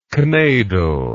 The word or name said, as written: knaidel